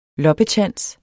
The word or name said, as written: loppetjans